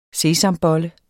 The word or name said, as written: sesamboller